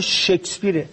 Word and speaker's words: شکسپیر: بعد تا بالاخره یکی از هنرمنده گفت آقا به خدا تلفظ این واژه شکسپیره.